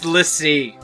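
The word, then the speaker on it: l'Cie: It's pronounced Lissy. – Lissy. – Channel your most stereotypical inner Frenchman for that apostrophe bullshit.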